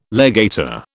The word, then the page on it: legator: British English